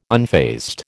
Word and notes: unfazed: American English

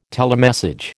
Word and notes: telemessage: American English

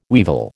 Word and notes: weevil: American English

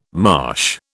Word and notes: marsh: British English

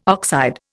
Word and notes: oxide: Indian

